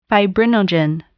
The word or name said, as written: fibrinogen